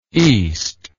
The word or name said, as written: eastness